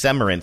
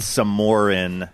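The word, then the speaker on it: Semoran: And he said it's pronounced Semarin. So when I get down there, when I roll down there, I want to sound... – I was calling it Samorin.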